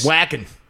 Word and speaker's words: Joaquin: Oh, Joaquin Phoenix? – Whackin'. It's pronounced whackin'.